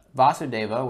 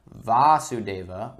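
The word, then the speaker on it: Vāsudeva: Vasudeva with a short a is Krishna's father. Vasudeva with a long a, so Vasudeva with a long a is Krishna himself.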